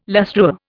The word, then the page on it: lustreware: Indian